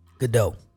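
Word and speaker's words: Gadot: Godot.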